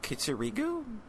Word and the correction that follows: Kiritsugu: Kitsurigu? – I don't know. Japanese pronunciation. – Ragu! – Yeah, it is Kiritsugu.